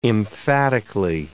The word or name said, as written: emphatically